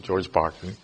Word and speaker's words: Berkeley: Spelled Berkeley, but this is the English pronunciation, George Berkeley.